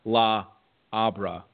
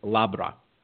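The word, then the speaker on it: La Habra: But I believe when you have a word like this, you just pronounce it as one. It's not La Abra. I believe it's Labra, California.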